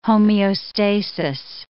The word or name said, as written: homeostasis